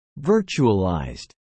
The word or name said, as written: virtualized